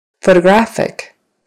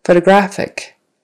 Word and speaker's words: photographic: Photographic. Photographic. We have new photographic evidence. Accent is on the third syllable now.